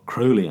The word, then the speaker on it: Crowley: That wonderful, weird magician, Aleister Crowley, or Crowley, I should say, rhymes with holy, that's what he always said, Aleister Crowley, stated that 11th is the number of magic in itself.